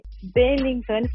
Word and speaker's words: Bellintani: podcast é Dona do Campinho dessa semana recebe uma presença especial, o presidente do Bahia, Guilherme Belintani.